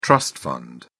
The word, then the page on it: trust fund: British English